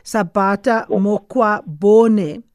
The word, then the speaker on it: Sabata Mokgwabone: Of course, it is Brigadier Sabata Mokgwabone.